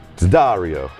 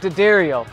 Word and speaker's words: D'Addario: And this is the Rig Rundown team, and we're sponsored by D'Addario. – Actually, I think it's pronounced D'Addario. – It's D'Addario, but no matter how you say it, we know it stands for quality.